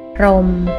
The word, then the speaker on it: พรหม: สวัสดีค่ะวันนี้พบกับรายการคำวัดขอเสนอคำว่าพรมคำว่าพรมสะกดด้วยพอพานรอเรือหอหีบมอม้าพรมคำว่าพรม